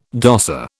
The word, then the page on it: dosser: British English